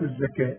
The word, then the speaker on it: الزكاة: وآتوا، قال الهمزة وسط السطر، وآتوا وآتوا الزكاة، الزكاة تكتب بالواو وتنطق بالألف. وآتوا